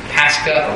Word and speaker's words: Pascha: I pronounced this word incorrectly, and should have known I was, but the actual Greek term is Pascha or Pascha. P-A-S-C-H-A, I believe,